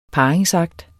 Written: parringsakt

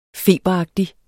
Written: feberagtig

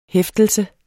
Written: hæftelse

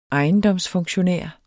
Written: ejendomsfunktionær

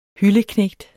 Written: hylde

